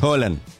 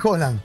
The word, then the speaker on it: Haaland: Haaland. – Y al, Ariel, claro. – No, no. – El profesor. – Erling Haaland, lo dijo él. – Erling Haaland.